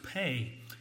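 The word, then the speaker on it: pay (פ): Psalm 119, we are in the stanza that is, the letter is pronounced pay, just like the English word P-A-Y is pronounced pay.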